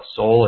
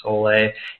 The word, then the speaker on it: sole: and it's spelled S-O-L-E. Some people call it soul. It's actually supposed to be soleil, and that is there's a recipe there.